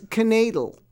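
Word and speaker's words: kneidel: It's knadel. You know, and it rhymes with madel, which means girl.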